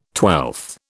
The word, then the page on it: twelfth: British English